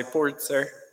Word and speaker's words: Purtzer: My last name is pronounced Pertzer, but normally here in Guatemala I say Pertzer because that's how Spanish speakers pronounce it.